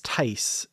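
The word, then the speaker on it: Theiss: William Ware, and I believe it's Tice is Tice. How I've heard it pronounced.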